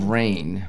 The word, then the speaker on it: Vrain: Vrain. What's the Vrain? What's Vrain? – How do you spell it? – V-R-A-I-N.